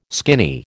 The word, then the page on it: skinny: American English